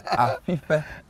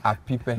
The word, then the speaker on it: Apipe: No, you're not saying it right. It's Apipe. It's like you're whistling. It's not Apipe.